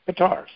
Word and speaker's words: Qatar: We don't have to say Qatar. We can just say guitar. It's like guitar, you know, it's G, but with a C. Qatar. Qatar. Guitar, Qatar. Okay? Think of it that way.